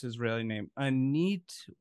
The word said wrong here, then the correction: Anat: Wolf. – Oh, Anat Wolf. Anat Wolf.